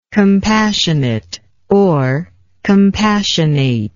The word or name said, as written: compassionateness